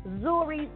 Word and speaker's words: Nzuri: Actually, I'm saying it incorrectly. It's spelled N-Z-U-R-I, but it's pronounced Nzuri Soul, which means beautiful.